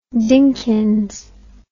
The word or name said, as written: Dinkins